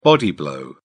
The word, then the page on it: body blow: British English